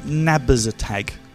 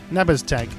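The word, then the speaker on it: Nabaztag: I reckon that's Nabazatag. – N-A-B-A-Z, Nabaz, Nabaz. Tag. Nabba's tag.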